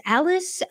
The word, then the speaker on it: Allis: I believe it's pronounced Allis, uh, Wisconsin, 51 miles to get to, to get to Kenosha, whereas Kyle Rittenhouse traveled 21 miles to get to Kenosha.